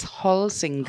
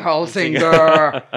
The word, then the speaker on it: Holsinger: It's Holsinger.